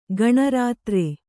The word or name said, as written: ಗಣರಾತ್ರ